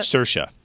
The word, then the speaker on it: Saoirse: How do you pronounce that? – Saoirse. – Is that how you pronounce that? – Yes. – Really? – Mm-hmm. Huh. Irish.